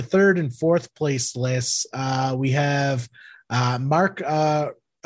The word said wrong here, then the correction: Racho: I hope I'm saying that right. – Racho. – Racho.